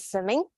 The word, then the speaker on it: Simming: Okay, so um, my name is Simming. Um, my name is like pronounced swimming without W, swimming, swimming.